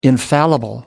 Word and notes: infallible: American English

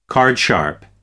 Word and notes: card sharp: American English

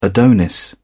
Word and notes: adonis: British English

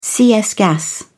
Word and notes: cs gas: British English

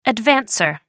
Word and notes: advancer: American English

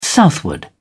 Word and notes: southward: British English

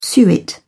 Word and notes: suet: British English

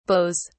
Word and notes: beaus: British English

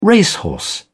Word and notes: racehorse: British English

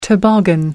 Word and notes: toboggan: American English

